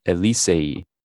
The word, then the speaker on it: Elysee: Oh, sorry. That's just totally horribly pronounced on my part. I didn't try to do this ahead of time. Let me think about it in my head for a second. Elysee. That's what it is. E-L-Y-S-E-E.